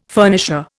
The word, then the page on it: furnisher: Indian